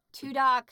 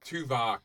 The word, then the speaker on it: Tuvok: Tudok. – Tuvok.